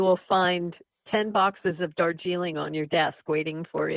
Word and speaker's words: Darjeeling: So forgive me for my ignorance and lack of knowledge, but I think it's page 8 when he sipped his dark jeering. Am I saying that right? And then put his mug back into a coaster. Am I saying that yes. right? – Darjeeling.